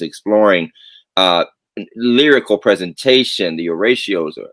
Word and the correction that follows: oratorio: Oratorio. Okay, I said it wrong.